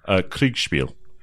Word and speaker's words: Kriegsspiel: Kriegsspiel.